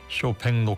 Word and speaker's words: 쇼팽: 미니의 이경민님 오늘 다양한 선곡 여기에 쇼팽의 녹턴 저는 왜 자꾸 쇼핑이라고 발음할까요? 쇼팽 녹턴까지 예, 가을 귀에 링거 맞고 보약까지 먹은 기분이라고 하셨습니다.